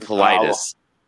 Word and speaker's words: Politis: We've got, you know, again, this is sort of a new thing to me with anybody like criticizing David Politis, sorry, I screw that up constantly. David Politis. I always want to say Pilates.